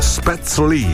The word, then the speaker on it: Spätzle: Spätzle si pronuncia Spätzle.